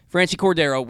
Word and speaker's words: Franchy Cordero: Franchy Cordero. – Franchy Cordero. Okay. – Franchy Cordero. Like okay. Franchy, but Franchy. – Okay. – Franchy Cordero with the 3-run homer.